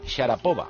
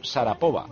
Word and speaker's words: Sharapova: Y este, para Pedrito Martín, dice, no me sorprende que a Juanma no le contestara Masha Sharapova, sobre todo si la llamó Sharapova, porque se pronuncia Sharapova.